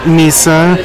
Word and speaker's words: Misa: I'm Misa, spelled M-I-S-A.